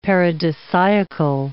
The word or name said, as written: paradisiacal